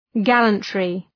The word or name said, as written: gallantry